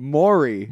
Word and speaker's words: Maury: And then there's cut to classic Murray cut. – Still said it wrong. – Man, how do I say it? – Maury. There you go.